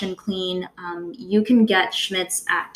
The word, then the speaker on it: Schmidt's: The one that I really like the most is called Schmitz. So it's spelled S-C-H-M-I, D as in dog, T as in Tom, S as in Sam.